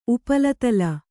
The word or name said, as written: ಉಪಲತಲ